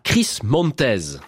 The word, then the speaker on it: Chris Montez: The More I See You, la version de 1966, chantée par Chris Montez. Ça se prononce comme ça.